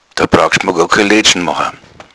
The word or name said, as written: laedschn